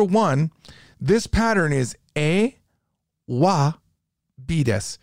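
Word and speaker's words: wa (は): When the topic marker in Japanese is written, it's written with this ha, but it's pronounced wa.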